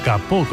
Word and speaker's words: capot: El alemán tomó prestado ese caput en el siglo XVII del francés capot, hoy pronunciado capot concretamente,